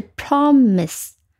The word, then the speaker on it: promise: 这个词是两个音节的，它的读音是 promise。那你注意到重音是在第一音节的，第一音节 p r o，它读成 pro，这个 o 发成短音，这个 o。